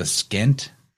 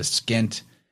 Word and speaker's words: Pschent: It's called the Skent, which is spelled P-S-C-H-E-N-T. So it has a silent P in there. The Skent, it combines the white crown of Upper Egypt and the red crown of Lower Egypt.